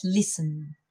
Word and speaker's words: listen: We have an S and a T, and I've heard a few students saying listen because, of course, there is an S and a T, but as you pronounced it, it, it all becomes a big S.